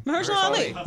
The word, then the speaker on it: Mahershala Ali: But like Mahershala Ali was like, how do you say his name? – Mahershala Ali.